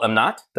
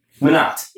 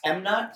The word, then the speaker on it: Mohnot: Did I say your last name right? – No. – Not even close. – No. – Say it for me. – Sheel Amnat. – Manat. – Yeah, you're close. – Come on, M-Not. – M-Not? – I wrote it phonetically as M-Not, Manat. – Yeah, close enough.